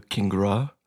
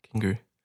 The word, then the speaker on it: Kingrea: Not Kingria. – No. – Or King some people, Ray. – Some people do Kingra. – Kingra. – Kingra. But it's Kingry. – Kingry. – Yeah. – Okay. – Silent A.